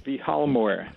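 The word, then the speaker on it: Vilhjalmur: I'll pronounce it Vihalmor.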